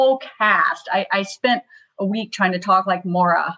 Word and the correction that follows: Moira: I'm probably mispronouncing her name, but I Moira. – Love that show. Moira.